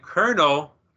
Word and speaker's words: Colonel: You know what one of my pet peeves when it comes to the English language is that Colonel... Like the kernel from fried chicken is not spelled like kernel.